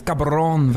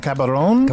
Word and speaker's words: Caball'on: I'm excited to talk to, I'm sure I'm mispronouncing this, Cabaron Valentin? – Oh, you are so very close, my friend. It is Cabaron Valentin. – Cabaron? – Cabaron Valentin. – Wow, he can really roll that. – Cabaron, yeah.